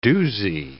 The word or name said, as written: doozie